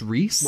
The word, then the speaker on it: Riese: It's Reese. I think it's pronounced Reese. – Martin Reese. – It is spelled R-I-E-S-E.